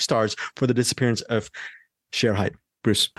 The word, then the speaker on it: Shere Hite: Sometimes they pronounce it, the media people, they pronounce it Shere Hite, but I think as far as the documentary goes, the correct pronunciation is Shere Hite, the disappearance of Shere Hite.